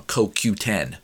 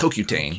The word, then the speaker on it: CoQ10: Except it's Co, the letter Q, and then the number 10. So I'm just saying it wrong. CoQ10, not CoQtane. CoQ10.